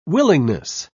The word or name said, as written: willingness